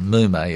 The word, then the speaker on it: mume: Yeah, I should have spelt it because the pronunciation is a little odd. Mume is M-U-M-E. So prunus, M-U-M-E, mume.